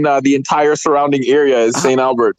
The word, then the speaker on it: St. Albert: I stayed in St. Albert.